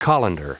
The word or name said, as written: colander